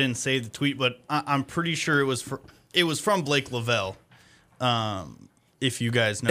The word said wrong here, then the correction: Lovell: Is it pronounced Lavelle? – I think it's Lovell. – Okay, Blake Lovell.